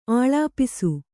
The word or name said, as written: ಆಲಾಪಿಸು